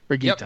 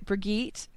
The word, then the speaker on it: Brigitta: I'm going to have Anne say it because I keep wanting to put a soft G in it, and that's not how it's said. How do we say her name? – I think it's Brigitta. – Brigitta, yep.